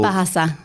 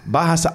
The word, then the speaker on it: Bahasa: Bahasa. – Bahasa. Oh, okay, right. – Yeah. – Bahasa.